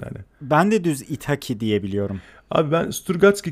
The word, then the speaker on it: İthaki: Gittim abi e, İtaki yayınlarından çıkan, böyle İtaki diye okunuyor değil mi? Yanlış bilmiyorum. – İtaki, İtaki, İtaki falan evet.